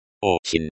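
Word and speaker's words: Ochin (очень): Ochin. Ocean, very. The stress is on the first syllable.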